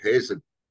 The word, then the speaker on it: Hazen: And my special guest right here right now is Harvey Hazen. Did I say that right? I forgot to ask that part at the beginning. – You did. That is correct, Hazen.